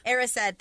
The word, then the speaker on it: Erised: And when he's in front of that mirror, which reminds me of the Mirror of Desire, of Ezered, however you pronounce it. – Erised, thank you.